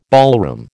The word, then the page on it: ballroom: American English